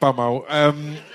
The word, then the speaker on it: bumhole: No, yeah, it's pronounced bumhole. Just so you know, just FYI, bumhole.